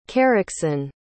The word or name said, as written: kerecsen